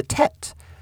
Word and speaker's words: Teth: And today, this is the ninth letter of the Hebrew alphabet, the Tet. It's spelled T-E-T-H,